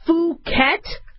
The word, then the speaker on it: Phuket: I don't mean to say it, but if I mispronounce it, it's Phuket, right? One of the strains in the vaccine, Phuket. I got to remember not to do a short U.